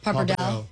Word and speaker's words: Pappardelle: I think Pappardelle, how do you say it? – Pappardelle.